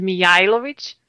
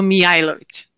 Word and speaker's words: Mijajlovic: My last name is Mijajlovic. So those J's are pronounced as a Y. And I know it's a lot of letters, but it's a bit difficult. So Mijajlovic.